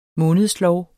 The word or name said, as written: månedslov